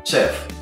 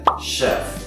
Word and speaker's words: chef: Cambia esa CH por un sh, que es como un sh. De esa manera, no digan chef, digan chef.